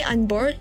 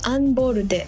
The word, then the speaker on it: unBORDE: I say Unboard, but it's pronounced Unborude, whose roster includes Capsule, Kyary Pamyu Pamyu, Tofu Beats, Wanima, and Indigo la End to name a few.